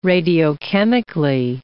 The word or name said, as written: radiochemically